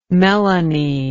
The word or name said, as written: melanie